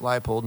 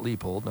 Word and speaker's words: Leipold: That's how it's pronounced. Leipold, not Leopold, or not Leopold, or, you know, Leonardo DiCaprio.